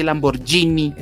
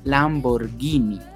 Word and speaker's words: Lamborghini: A mí personalmente me parece muy bonito, y no se dice Lamborghini. – Es Lamborghini. No, dice Lamborghini.